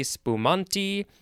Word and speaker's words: spumante: First word is capital A-S-T-I. Second word is capital A. No. What? Capital S-P-U-M-A-N-T-E. Looks like it has some other possible pronunciations. Asti spumanti, asti, various things.